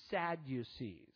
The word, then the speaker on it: Sadducees: It also mentions the Sadducees. Now, I like this name because if you pronounce it slowly, it defines itself, sad you see, because that pretty much sums this group up.